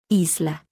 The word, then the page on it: i: letter; Spanish alphabet